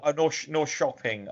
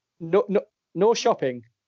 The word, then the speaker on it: Norrköping: And he plays for IFK Norrköping in Sweden. So Norrköping, I think it's pronounced. – It is. You're right. – Norrköping.